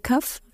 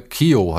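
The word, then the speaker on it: Keough: Kari Kaff? Wird sie so ausgesprochen? Riley Cuff? – Riley Kio heißt, glaube ich, wird sie ausgesprochen. – Q? Also ich glaube, oh, sie wird Kio, okay. Kio ausgesprochen.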